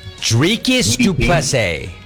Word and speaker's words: Dricus du Plessis: Dricus du Plessis.